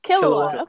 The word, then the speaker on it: Killua: Killua? – Killua.